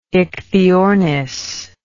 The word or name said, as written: ichthyornis